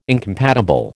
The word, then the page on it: incompatible: American English